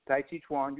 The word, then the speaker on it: Tai Chi Chuan: Tan Chi Chuan? – Tai Chi Chuan, you got it right, Tai Chi.